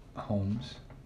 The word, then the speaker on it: Holmes: So so Adrian Holmes, or I don't know how to say that properly with my Yinzer accent, because I want to just say Holmes. And that L just completely disappears. But then I try to overcompensate and say the L, and then it's Holmes. So how would you say it? – Holmes.